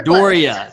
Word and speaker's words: D'Auria: It's spelled much more intimidating than how you actually pronounce it. – Totally. Well, it's really Daria, but we just go Doria. It just makes it easier.